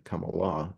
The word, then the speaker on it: Kamala: On the one hand, they are having Benny Gantz meet with Kamala, which is how it's pronounced properly, Kamala in Hindi.